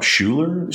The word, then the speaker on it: Schuyler: It's spelled the Dutch way, S-C-H-U-Y-L-E-R, which is kind of funny when people see it. They're like, oh, Schuyler is your middle name? But Schuyler, just the Dutch way of spelling it, which is kind of cool.